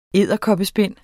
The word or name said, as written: edderkoppespind